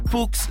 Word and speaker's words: Fuchs: That's F-U-C-H-S, pronounced Fuchs, is a global lubricant specialist with nearly 100 years of German engineering technology, research and development behind the brand.